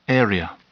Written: area

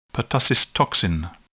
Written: pertussis toxin

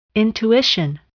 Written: intuition

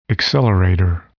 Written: accelerator

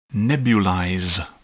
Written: nebulize

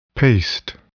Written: paste

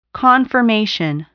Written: confirmation

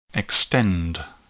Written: extend

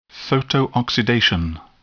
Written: photooxidation

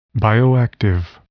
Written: bioactive

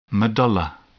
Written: medulla

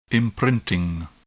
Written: imprinting

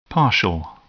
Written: partial

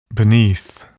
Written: beneath

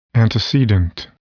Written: antecedent

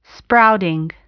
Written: sprouting

